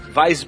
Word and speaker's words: Weiss: Uma Wise Beer aí que o pessoal tava falando. Não, Weiss Beer. Weiss, desculpa, desculpa a pronúncia.